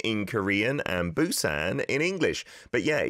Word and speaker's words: Busan: You can still find it from time to time, spelled P-U-S-A-N, Busan. And a B, I suppose, is it's closer to a B, I would say, in terms of the sounds, but it's not a million miles away from a P either, depending on which vowel sound it is paired up with. But yeah, the P, like in Pita, that is closer to a P, I would say, overall.